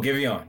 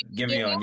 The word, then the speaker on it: Giveon: Oh, Giveon. Yes. Yeah, yes. You said it right. Giveon.